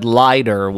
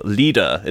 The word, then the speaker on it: Lieder: I would have said Leider, which is not how that's pronounced. – Leider. No, Lieder. It's German.